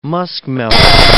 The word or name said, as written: muskmelon